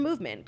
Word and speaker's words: MVMT: Be the big winner this holiday season with a gift from Movement.